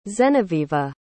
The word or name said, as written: zenevieva